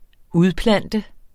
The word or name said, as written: udplante